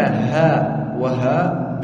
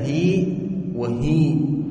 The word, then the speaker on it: هاء وهاء: إلا هاء وهاء بالمد على الأفصح عند العلماء كما نطقت لكم بها، إلا هاء وهاء، وقيل بكسرة إلا هي وهي، وقيل بالسكون إلا هاء وهاء، وقيل وحكي إلا هاكي وهاكي.